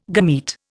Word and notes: gamete: Indian

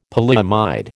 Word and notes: polyamide: American English

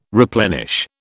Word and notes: replenish: British English

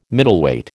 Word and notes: Middleweight: American English

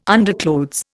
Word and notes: underclothes: Indian

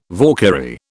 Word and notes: Valkyrie: British English

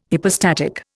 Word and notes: hypostatic: Indian

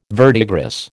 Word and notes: verdigris: American English

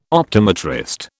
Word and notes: optometrist: British English

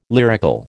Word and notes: lyrical: American English

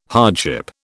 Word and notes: hardship: British English

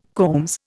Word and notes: combs: Indian

